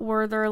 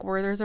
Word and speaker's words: Werther: It's not Werther like Werther's original. If you're pronouncing it that way, it's an honest mistake. I get it. It is pronounced Werther. – Yeah. – Because it is in French.